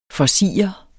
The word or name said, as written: forsirede